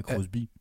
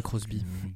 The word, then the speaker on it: Crosby: Crosby, je ne sais pas la prononciation. Je dirais Crosby. ouais, Crosby.